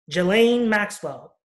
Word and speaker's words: Ghislaine: Ghislaine Maxwell, am I saying it correctly? – Ghislaine Maxwell.